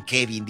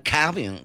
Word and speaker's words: Kevin: No dicen Kevin, dicen Calvin, que sé cómo le hacen.